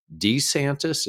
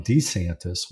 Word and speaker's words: DeSantis: I recall a few weeks ago seeing an article that Casey DeSantis had actually encouraged him to change the pronunciation of his name from DeSantis, which is what he's used when he was elected to Congress originally, to DeSantis.